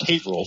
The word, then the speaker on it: Haverhill: And those who don't live around Massachusetts, it's Haverhill. It's pronounced Haverhill.